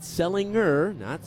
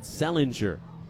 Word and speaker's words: Sellinger: Selinger spelled S-E-L-L-I-N-G-E-R. But not Selinger, but Selinger. A G pronounced like a J.